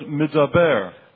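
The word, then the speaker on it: Midaber (מדבר): There are two words in Hebrew that look alike and are spelled alike. – 有两个希伯来字看起来一样，而且发音起来也差不多。 – One is the word Midbar. – 第一个字就是 Midbar，就是旷野的意思。 – And the second is the word Midaber. – 另外一个字就是 Midaber，就是上帝说的意思。 – It's spelled the same way in Hebrew. – 在希伯来字，它其实是它的那个发啊、就是拼法是一样的。 – But they have completely different meanings.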